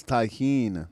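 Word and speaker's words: Tajín: that spice is Tajin. – Oh, fuck. Ah, it's tahine.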